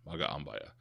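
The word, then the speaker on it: Magaambya: Each syllable, each vowel is pronounced by itself. So it's Magambaya.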